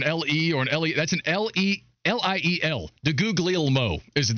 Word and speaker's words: DeGuglielmo: What was it? Guglubio? – DeGuglielmo. – DeGuglielmo.